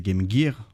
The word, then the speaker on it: Game Gear: La prononciation exacte c'est Game Gear en anglais.